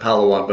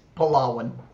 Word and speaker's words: Palawan: Oh, so that's on, and I always pronounced it Palawan, but that's not correct, as it's It's Palawan. – Palawan. Okay, so Palawan.